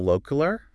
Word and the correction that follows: alquiler: Como alquiler. Okay, so that means like how to rent or something.